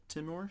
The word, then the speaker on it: Timor: The 20-meter boat was then found abandoned in the Timor, Timor Sea. Am I saying that right, Marcus? Sure.